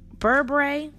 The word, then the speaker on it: berbere: You're going to need one tablespoon of minced garlic, one tablespoon of minced ginger, one fourth cup of beret or bur, berbere, it's spelled B E R B E R E, which is an Ethiopian spice blend.